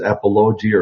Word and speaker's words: apologia: And that word is apologia or apologia. I don't know how to say it. Do you? Apologia.